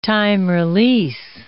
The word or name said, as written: time-release